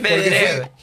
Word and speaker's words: Medvedev: El otro partido de cuarto de final que restaba era el de Daniil Medvedev. Mira cómo aprendí. Venció en 3 sets al español Martín Landaluce. – Muy bien cómo separas en sílabas. – Igual es Medvedev.